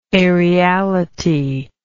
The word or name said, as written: aeriality